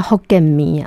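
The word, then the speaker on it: Hokkien mee: 哎，对他们当地人叫 Hokkien mi 啊，他们有这样子的发音，确实是。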